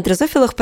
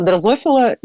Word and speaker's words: дрозофила: Я правильно ударение ставлю? То я всегда это в текстом читаю. На самом деле, да, дрозофила. А, дрозофила и люди, которые говорят дрозофила, очевидно, никогда в жизни не работали в лаборатории с дрозофилой.